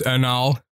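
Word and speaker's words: Anaal Nathrakh: but another band is Anal Nathrak. Yes, it's pronounced Anal. I think it's Anal Nathrak.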